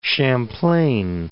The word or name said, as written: Champlain, Lake